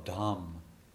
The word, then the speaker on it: Dham: Oh, Dham. D-H-A-M. Dham. Abode.